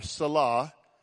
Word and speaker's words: Selah: Now that Selah, or Selah, as that's correctly pronounced, it's not real clear what it means.